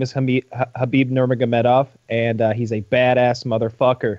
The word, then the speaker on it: Khabib Nurmagomedov: Khabib Nurmagomedov. – Nice pronunciation. I'm impressed.